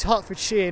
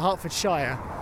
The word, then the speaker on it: Hertfordshire: I was just thinking that, actually, because I hear it a lot on TV, where people go, oh, somewhere Shire, but it's not, it's Shear, as in like, you know, a pair of garden shears. That's how we pronounce it, like it's shears. So this is Hertfordshire, not Hertfordshire. Going back to what I was just talking about with pronunciations, so here's a classic example, right?